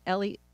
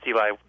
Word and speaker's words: Eli: First, I want to welcome Eli Pariser. Did I pronounce that correctly? – Yeah, it's Eli.